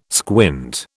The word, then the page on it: squint: British English